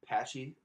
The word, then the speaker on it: Pache: We got to figure out how to pronounce this guy's name. – It's Patchy. – Patchy? – Patchy. – I'm going to say Pache.